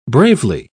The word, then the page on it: bravely: British English